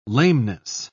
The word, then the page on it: lameness: British English